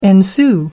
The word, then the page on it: ensue: American English